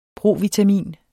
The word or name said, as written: provitamin